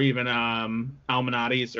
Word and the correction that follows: Malnati's: or Almanados. Uh, I forget how you pronounce that. Anyway, but or sorry, Lou Malnati's.